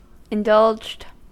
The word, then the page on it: indulged: American English